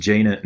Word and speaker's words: Jaina: I'm pretty sure it's just Jaina and Jacen.